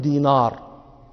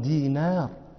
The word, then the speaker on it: دينار: النون رقيقة، النون حرف رقيق، لا نقول دينار، هذا نطق عجمي، نحن عرب نقول عبد الله بن دينار، دينار نون هذا حرف رقيق.